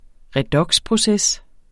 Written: redoxproces

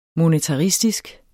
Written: monetaristisk

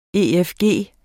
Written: efg

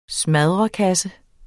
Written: smadderkasse

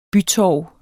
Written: bytorv

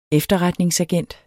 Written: efterretningsagent

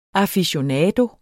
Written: AFICIONADOERNE

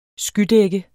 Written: skydække

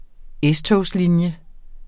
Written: S-togslinje

